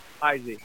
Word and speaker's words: Heisey: Is it Heisey or Heisey? – Heisey. Heisey. Yeah.